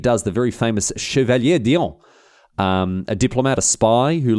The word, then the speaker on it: d'Orléans: Louis-Philippe Joseph d'Orléans, butchered that one.